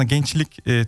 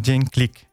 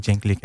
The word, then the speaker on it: Cenklik: Aynen, normal aslında gençlik Türkçe biz telaffuz ediyoruz ama Cenklik diye geçiyor.